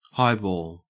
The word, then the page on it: highball: Australian English